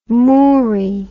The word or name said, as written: moory